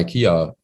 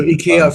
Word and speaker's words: IKEA: Die quasi also IKEA für die, das ist ja die englische Aussprache, nicht das. – So, okay. IKEA.